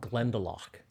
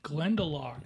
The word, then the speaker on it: Glendalough: There's one that is aged in sherry casks and in bourbon barrels, and it is called Glendalough. – Glendalough. – Yep. Drinks like it's $100 a bottle. – Yeah, let me write that down. Do you know how to spell that? – Yeah, Glen, G-L-E-N-D-A-L-O-U-G-H. – L-O-U-G-H.